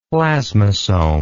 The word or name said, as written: plasmosome